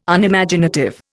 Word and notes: Unimaginative: Indian

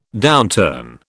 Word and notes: downturn: British English

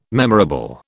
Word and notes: memorable: British English

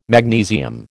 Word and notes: magnesium: American English

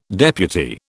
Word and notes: deputy: British English

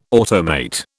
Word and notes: automate: British English

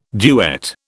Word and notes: duet: British English

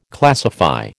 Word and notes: classify: American English

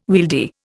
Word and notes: wieldy: Indian

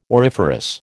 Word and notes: auriferous: American English

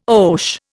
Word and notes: oche: Indian

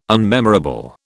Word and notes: unmemorable: British English

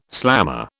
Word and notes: slammer: British English

